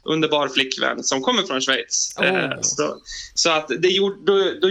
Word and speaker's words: Schweiz: jag tror jag säger rätt nu, Schweiz. – Ja, Gustav Fridolin hade ju lite problem med det för några år sedan, men det stämmer. Schweiz.